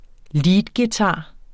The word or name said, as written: leadguitar